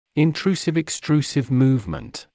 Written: intrusive-extrusive movement

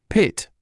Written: pit

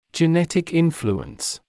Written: genetic influence

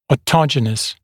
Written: autogenous

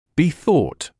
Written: be thought

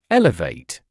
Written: elevate

